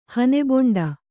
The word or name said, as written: ಹನೆಬೊಂಡ